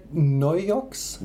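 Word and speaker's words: Naujocks: Well, while we're pronouncing things, maybe you could, before I even give it a try, how would you pronounce that last name? – Alfred Neujochs.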